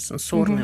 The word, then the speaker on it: сенсорный: Я вспоминаю свой какой-то опыт столкновения с медиаискусством и вот сенсорный опыт, да, ударение сенсорный угу.